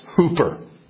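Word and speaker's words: hooper (ὑπέρ): Prior to the word church, ekklesia, is the prepositional or preposition pronounced hooper,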